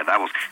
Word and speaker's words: Davos: Y eh, te digo Davos y no Davos porque así se pronuncia Davos.